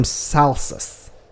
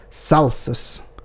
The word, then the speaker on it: salsus: And the sausage derives from salsus. Means salted in Latin. – Wait, say it again. – Salsus. Salsus. – Can you spell it for me, please? – Oh, yeah, sorry. S-A-L-S-U-S.